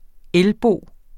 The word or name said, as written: elbo